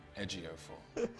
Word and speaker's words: Ejiofor: Ejiofor.